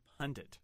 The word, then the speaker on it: Pundit: There's no extra N. – On the last syllable. We got an email about that. – Pundit. – Pundit. – P-U-N-D-I-T for mm-hmm.